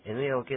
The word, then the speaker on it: 縫え: 縫えという発音です。縫いえではないんですね。